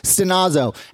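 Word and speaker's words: stenazo (στενάζω): And the Greek word for groaning is pronounced stenazo.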